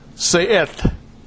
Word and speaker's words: se'et: The phrase, be lifted up, is taken from the Hebrew verb there, se'et. Se'et, that's how you pronounce that thing.